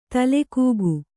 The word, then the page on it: ತಲೆಕೂಗು: tale kūgu; Noun